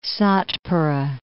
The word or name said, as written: Satpura Range